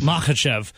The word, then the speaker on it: Makhachev: Islam Makhachev.